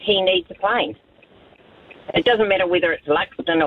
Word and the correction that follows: Luxon: Hey, someone had to go at me for mispronouncing the Prime Minister's name, which I did not do. Luxon. – Oh, right, Luxon.